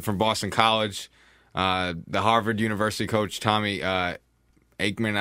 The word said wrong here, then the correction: Amaker: I don't want to say it wrong. – Amaker, thank you.